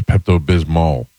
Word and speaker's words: Pepto-Bismol: Bismol? Yeah, I guess that would probably be, I've always called it Pepto-Bismo, but it is an L on there, so Pepto-Bismol.